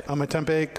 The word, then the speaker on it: Ometepe: And the filler is a blend of Ometepe. Did I say that right? – Ometepe, Condega, and Esteli tobacco.